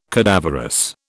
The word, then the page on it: cadaverous: British English